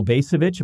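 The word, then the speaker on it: Bacevich: This is from Colonel Basevich. Am I pronouncing that correctly? – Yes.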